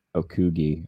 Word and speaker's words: Okogie: So I did a first basket of Brooke Lopez, Anthony Edwards, and Josh Okogie. I don't know. I probably just butchered his name. – No, you got it. Josh Okogie.